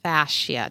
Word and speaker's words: fascia: And Kate, I know you specialize in something called the fascia.